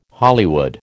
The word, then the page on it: Hollywood: American English